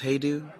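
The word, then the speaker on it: Hajdu: Lush Life by David Hodgdew. – I think it's pronounced Heydew.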